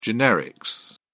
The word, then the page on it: generics: British English